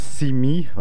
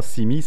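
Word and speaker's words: Se mig: Mejram avec Semi, enfin eux ils prononcent Simi, ouais, effectivement, Simi, ça se rapproche très fort de l'anglais Simi, qui voudrait dire vois-moi.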